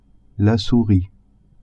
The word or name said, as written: souris